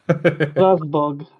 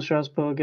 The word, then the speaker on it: Strasbourg: I've listened to podcasts, and apparently we got to say it in a more, way more French way. – Strasbourg.